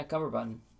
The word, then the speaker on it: Cumberbatch: Yeah, Cumberbatch.